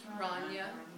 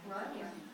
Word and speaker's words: Rania: How do you say the name? – Rania. – Rania.